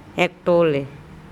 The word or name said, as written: ekTole